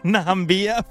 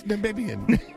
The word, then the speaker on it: Namibian: Na, nam, na, Nambia fans. – No, it's, it's Namibian.